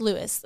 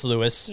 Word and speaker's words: Louis: It's spelled L-O-U-I-S. – So it's Lewis. That's how we pronounce it. – I would say it's Lewis.